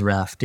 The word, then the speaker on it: Raftt: Is it Raftt? Is that how I say it? – It is Raftt, yeah.